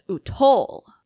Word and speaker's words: Utol: I think we just need to put the emphasis on the second syllable so that it's Utol system.